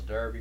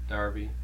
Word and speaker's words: Darby: My name is Charles Darby.